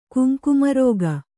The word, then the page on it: ಕುಂಕುಮರಾಗ: kannada